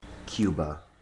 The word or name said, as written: Cuba